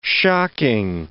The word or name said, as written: shocking